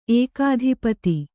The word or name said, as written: ಏಕಾಧಿಪತಿ